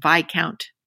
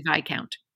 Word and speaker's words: Viscount: And I do have to correct your pronunciation of the word Viscount, Shash. It is actually Viscount.